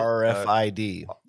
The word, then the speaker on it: RFID: You know, if you want miniatures that carry data, in essence, you just put an RFC. Is that how you say it? – RFID. RFID is chip.